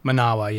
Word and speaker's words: Manawa: You were involved with the group, and I, am I saying this right? Is it Manawa? Manawa, yes, you're correct.